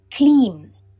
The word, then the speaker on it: CLEAM: First, let me demonstrate the pronunciation. CLEAM, CLEAM, CLEAM. It rhymes with beam, CLEAM. Now let's practice together.